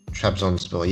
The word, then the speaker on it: Trabzonspor: Trabzonspor.